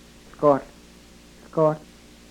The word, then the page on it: hlkohl: yurok